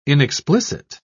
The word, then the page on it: inexplicit: British English